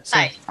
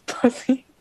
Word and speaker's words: Psy: Psy. Okay, I used to pronounce it as Pussy.